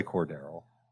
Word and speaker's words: Cordarrelle: So if you think he's going to keep making these explosive plays, then by all means, start Cordarrelle, enjoy Cordarrelle.